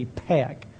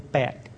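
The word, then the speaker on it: Pheak: I think I even spell her name T-E-E-M in our prayer letter. Peck is spelled P-H-E-A-K. P-H is not the F sound in Khmer. It's an aspirated P, Peck, as opposed to Peck, which is unaspirated.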